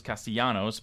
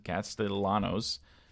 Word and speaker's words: Castellanos: Castellanos, even though I thought it was Castellanos, but apparently he pronounces it Castellanos.